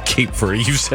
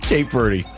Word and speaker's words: Cape Verde: Cape Verde, by the way. Cape Verde. You've said it wrong twice. Cape Verde.